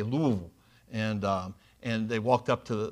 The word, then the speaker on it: Louisville: By the way, I had two roommates in college that were from Louisville, and you say it Louisville, like kind of got a mouthful of food, and it's two syllables, okay, Louisville.